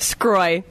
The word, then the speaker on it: Sgroi: Did I get that right? – Sgroi. – Sgroi. – Oh, it's kind of a tough one.